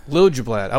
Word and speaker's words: Liljeblad: Ian, how do I say your last name? – Liljeblad. – I was going to mess that up. I've known you for like 10 years. Now, Monica says it, but I don't think she says it right, though, honestly. – It's just like it's spelled.